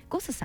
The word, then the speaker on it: GOSS: このですねシュリンプガーデンをはじめ他にもね素敵なお店たくさんございまして、銀座にシーフードレストラン火山さん、そしてすぐお隣にございますバーレストランゴスさん、 GOSS と書いてゴスさん、このね 2 店舗でもね天使のエビを扱っておりますのでね、召し上がることができます。